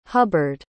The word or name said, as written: hubbard